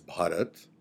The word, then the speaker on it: Bharat: So I'll preface that by saying my name is Bharat, which is the right way to pronounce it. I'm going to give some leeway to people who speak the English language because there is no word in the English language in which the letter B is followed by the letter H.